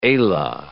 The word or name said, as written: ala